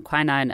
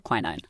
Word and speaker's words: quinine: Before we go any further with the cinchona tree and quinine and tonic water, we need to have a language moment. I'm American, I pronounce the word quinine. We say quinine.